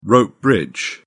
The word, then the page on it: rope bridge: British English